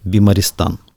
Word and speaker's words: Бимаристан: И я думаю, вот хорошо, был этот Бимаристан, Правильно сказал? Да-да.